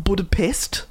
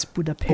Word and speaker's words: Budapest: We had to look it up because it's not Budapest. – No. No, it's Budapest. – Or Budapest.